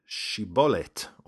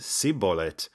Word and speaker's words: shibboleth: they would ask the person to pronounce the word shibolet, or shibboleth. Because, you see, Ephraimites had a little bit of an impediment around the sh sound. They said sibolet, which would result in them being killed on the spot by the Gileadites.